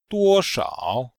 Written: duo1shao3

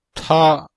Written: ta1

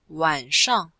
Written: wan3shang4

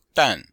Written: dan4

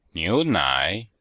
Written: niu2nai3

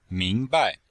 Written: ming2bai5

